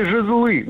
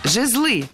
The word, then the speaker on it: жезлы: Жезлы. Неверно. Правильно, жезлы. Ударение в этом слове ставим всегда на первый слог, ведь оно тоже неподвижное. Поэтому жезлы, жезлом, жезлами, жезлов.